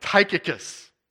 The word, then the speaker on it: Tychicus: And so, like Tychicus is how it's pronounced. I've said Tychicus and Tychicus both.